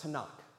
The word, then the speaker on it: Tanakh: it's sometimes referred to by scholars and others by the three letters T, N, and K, or it would be pronounced Tanakh.